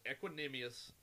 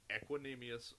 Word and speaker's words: Equanimeous: No, that is exactly how you pronounce that name. It's Equanimeous St. Brown. Let's all enunciate and say it together, ladies and gentlemen. Equanimeous St. Brown.